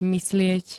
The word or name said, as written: myslieť